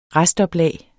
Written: restoplag

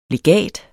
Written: legat

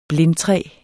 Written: blindtræ

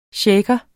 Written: shaker

